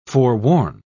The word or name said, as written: forworn